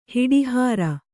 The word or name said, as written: ಹುಡಿಹಾರು